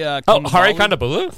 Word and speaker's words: Kondabolu: Hari Kondabolu.